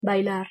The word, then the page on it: bailar: Spanish